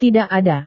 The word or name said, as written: tidak ada